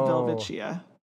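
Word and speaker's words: Welwitschia: It's spelled Welwichia. But it could be pronounced Velvichia.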